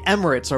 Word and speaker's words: Emirates: Is that how you say it? Oh, I never said it. – E-M-I-R-A-T-E-S. Yeah, Emirates.